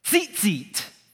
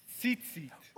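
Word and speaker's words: Tzitzit (ציצית): Tzitzit. – Tzitzit. – He said that perfect. It's T-Z, if you were to spell it in English, it would be like T-Z-I-T-Z-I-T, like T-Z-Z-T, but it's pronounced tzitzit.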